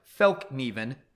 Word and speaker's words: Fällkniven: Um, but the reality is, it is pronounced Fällkniven, pressure on first and second syllables where ni is pronounced and an audible k and ven pronounced as in raven without the ra of Raven.